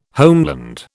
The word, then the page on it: homeland: British English